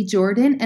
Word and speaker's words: Jordan: And if you want to find me directly, it's at It's Haley Jordan. And my name is spelled H-A-Y-L-E-E and Jordan, J-O-R-D-A-N.